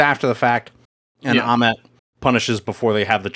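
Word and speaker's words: Ammit: If Moon Knight is the avatar to the moon god Khonshu, Arthur Harrow is the avatar, or the would-be avatar, to the goddess Amit, right? Is that how it's pronounced? – Yes. Yeah.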